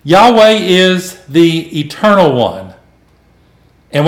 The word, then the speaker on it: Yahweh: And so now we have this word that's spelled out, YAHWEH. And for the most part, the majority of people are going to pronounce it as Yahweh.